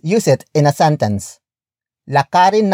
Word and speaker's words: lakarin: The first word is lakarin, which is spelled as L A K A R I N.